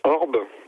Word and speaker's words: orbh: Il y a une racine indo-européenne qui se prononce Orb, donc c'est O-R-B-H, qui désigne à la fois un petit enfant, un orphelin et un esclave.